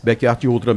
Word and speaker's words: Backyard Ultra: Vê se eu pronuncio correto, viu? Backyard Ultra, é isso? Backyard Ultra mesmo.